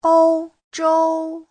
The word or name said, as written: ou1zhou1